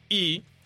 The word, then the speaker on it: Rhee: Es el señor Syngman Rhee, lo vamos a decir Rhee, aunque se pronuncia, según Naomi, mi amiga, en I.